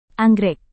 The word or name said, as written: anggrek